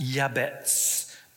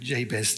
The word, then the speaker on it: Jabez: In Hebrew, it's pronounced Yabetz. But we're just going to stick with Jabez today, okay?